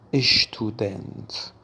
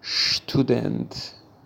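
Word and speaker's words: Student: دقت بکنید دوستان، هیچ وقت نگید اشتودنت، تلفظ نادرستیه و تلفظ صحیح شتودنت میباشد، شتودنت.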